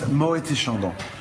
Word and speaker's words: Moët et Chandon: But if you put the two together, it's Moët et Chandon. So the T, it's pronounced if you put the two names together.